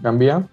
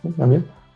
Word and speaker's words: Gambia: Bueno, pues eh, hubo partidos esta, este fin de semana de, la Copa Africana y el primero fue Camerún contra Gambia. Sí, sí está bien pronunciado, Gambia. Gambia.